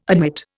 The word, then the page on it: admit: Indian